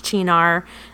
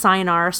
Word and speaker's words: Cynar: Most people do not know it's pronounced Chinar. They know it's pronounced Cynar, so I will often say that just so everybody knows what the heck I'm talking about.